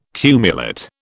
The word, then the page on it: cumulate: British English